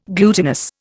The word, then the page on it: glutinous: Indian